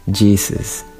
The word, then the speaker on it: Jesus: Y ah, Jesús en inglés se pronuncia Jesus.